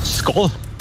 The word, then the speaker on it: Skål: Skål.